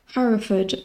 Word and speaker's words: Hereford: So I'm thinking of places like Hereford or Salford. The last part is F-O-R-D, so it looks like it should be pronounced Ford, but we actually say Fud.